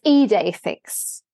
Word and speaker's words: Idefix: But it's Idefix.